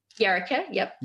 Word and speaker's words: Yaraka: Women of, I hope I'm pronouncing this right, Yaraka? – Yaraka, yep. – Yaraka, beg your pardon, Yaraka.